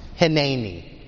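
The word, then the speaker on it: hineni (הנני): It's hineni. You would spell that in English, H-I-N-E-N-I. Hineni.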